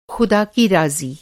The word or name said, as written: रा